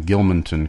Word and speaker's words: Gilmanton: Gilmanton Iron Works, New Hampshire, and I'm hoping I'm pronouncing Gilmanton correctly. – That is correct, actually.